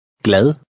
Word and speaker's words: glad: At the beginning of a word, the G is pronounced. Be careful of your pronunciation of the soft D. – Glad.